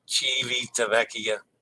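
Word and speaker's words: Civitavecchia: Civita. Did I say that wrong again? Civita Vecchia. Civita Vecchia. Civita Vecchia. Sorry. Civita. Oh, Civita Vecchia. Civita Vecchia. I had two syllables wrong.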